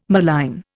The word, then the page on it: malign: Indian